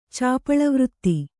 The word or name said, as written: ಚಾಪಳವೃತ್ತಿ